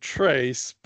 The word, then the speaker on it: Tres: I just got to interrupt you there because my dog, you know, I named my dog Trace, but it's spelled T-R-E-S. It's Spanish for three because he's sort of my third dog.